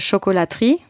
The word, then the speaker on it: chocolaterie: And the chocolate, how do you say that? It's a chocolaterie. – Yeah, we like the shop is called the chocolaterie. – Okay. – Which you have like pâtisserie, boulangerie.